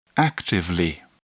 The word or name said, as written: actively